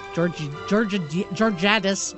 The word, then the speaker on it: Georgiadis: Georgiadis. I have no idea how you pronounce her name. – I'm almost certain that's correct. The last one there. That's what you should say for the yeah. – Georgiadis, saying she would oversee global sales, marketing, and operations.